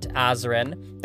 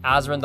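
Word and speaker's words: Azren: It is at Polyglot Azrin. That is spelled P-O-L-Y-G-L-O-T, A-Z or Z, depending on your country, R-E-N. You can also simply search Azrin the Language Nerd on whatever your favorite social network is.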